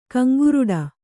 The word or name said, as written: ಕೆಂಗೋರಟೆ